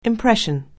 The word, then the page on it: impression: anglais